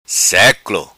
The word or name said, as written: Seclo